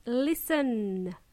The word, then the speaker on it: listen: The T is silent. I don't want to hear listen. It's listen.